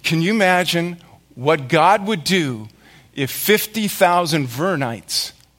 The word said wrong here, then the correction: Vernonites: is that how you say it? Vernonites would show up and say, "Read us the Bible!